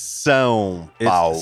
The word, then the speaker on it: São Paulo: It's São Paulo.